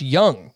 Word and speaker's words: Jung: Josh Jung. Not Jung. Josh Jung.